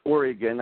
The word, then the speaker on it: Oregon: By the way, it's pronounced Oregon.